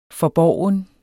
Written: FORBORGNE

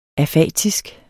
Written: afatisk

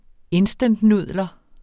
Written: instantnudler

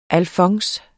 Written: alfons

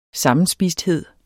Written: sammenspisthed

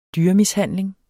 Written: dyremishandling

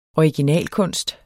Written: originalkunst

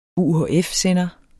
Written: UHF-sender

